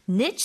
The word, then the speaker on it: niche: 지금 뭐 archery 같은 거 뭐 양궁 or speed skating 같은 것은 살아남았다라고 얘기 나오는데 이런 것들을 niche sports나 아니면 발음이 또 하나 있죠.